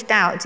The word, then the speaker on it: doubt: So they took the old English doubt, D-O-W-T and D-O-U-T, and thought, well, we're just going to put a B in there, but we won't pronounce it.